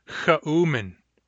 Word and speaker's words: chaumin: Chaumin, pigeon, chaumin, three syllables, cha, u, min, cha, u, min.